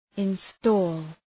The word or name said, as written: install